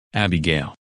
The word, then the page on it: Abigail: English